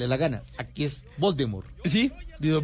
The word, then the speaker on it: Voldemort: porque según ella no se pronuncia la T del final, sino que se pronuncia Voldemort no, Voldemort. Y todos estábamos diciendo Voldemort, porque estaba la puesta la T ahí, de Voldemort.